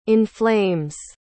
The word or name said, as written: inflames